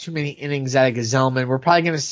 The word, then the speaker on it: Gsellman: Robert Gsellman, That's not how his name looks, but it is pronounced that way, folks.